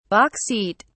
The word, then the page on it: box seat: British English